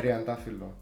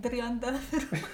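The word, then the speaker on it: Triandafilo (τριαντάφυλλο): So where can we get, how do you say it? – Triandafilo. – Where do we get Triandafilo? – you said it quite well, actually.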